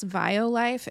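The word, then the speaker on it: Violife: For tacos, I like the, I think it's pronounced Vio-Life, V-I-O Life, and it's just shredded cheddar.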